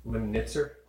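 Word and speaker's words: Lemnitzer: And the next move was Operation Northwoods, which was basically this document that was Lehman Limpsender. How do you say his name? – General Lyman Limnitzer.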